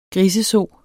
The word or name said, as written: griseso